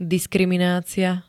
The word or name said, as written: diskriminacia